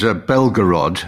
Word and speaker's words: Belgorod: This is Belgorod. I think I mispronounced it earlier on.